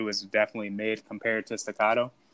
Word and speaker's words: staccato: Staccato. Did I say that right? – Yeah, you got staccato.